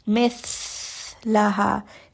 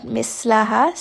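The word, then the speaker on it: مثلها: So this is not a letter seen, this is the letter tha. Mithlaha, not mislaha.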